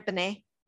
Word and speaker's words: Binet: Am I pronouncing that right? Binet? Binet. Okay. Yeah.